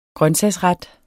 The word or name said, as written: grøntsagsret